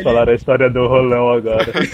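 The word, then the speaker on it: Rollo: Essa é a pronúncia dele. Fala em português de Portugal mesmo. Rolão. O cara chama ele de rolão.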